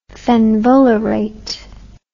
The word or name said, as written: fenvalerate